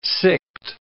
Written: sicked

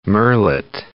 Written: murrelet

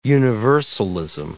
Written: universalism

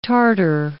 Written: tartar

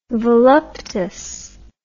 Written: voluptas

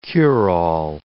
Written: cure-all

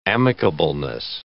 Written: amicableness

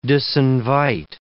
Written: disinvite

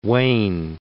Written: Wayne

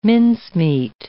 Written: mincemeat

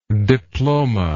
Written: diplomad

